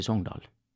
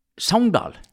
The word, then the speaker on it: Sogndal: Det er ingen i Sogndal som sier Sogndal. De sier Sogndal! Ja. og i nabobygda der jeg er ifra, så sier vi ikke Sogndal, vi sier Sogndal.